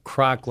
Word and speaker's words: Kraak: Well, it's more pronounced Kroc, like crock pot, but like, okay.